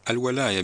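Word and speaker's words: الوَلاية: نعم، الولاية بفتح الواو طبعاً. هنالك الولاية، لأنه لو قلنا الولاية معناه الحكم، ولكن الولاية النصرة يعني.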